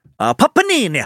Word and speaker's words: Papini: And that family member was related to an ex-boyfriend of Panini's. Oh, that's how you say it. You know what? It keeps reminding me of the fucking sandwich of a panini. It's Papini.